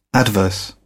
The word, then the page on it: adverse: British English